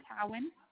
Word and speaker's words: Cowan: Isaac, I-S-A-A-C. – I-S-A-A-C. – And then he's got the same last name, Cowan. – Spell it again for me. – C-O-W-A-N.